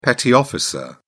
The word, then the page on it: petty officer: British English